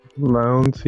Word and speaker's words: Lowndes: And Lowndes, I think that's how you say it. Lowndes. Lowndes. – Lowndes, yeah.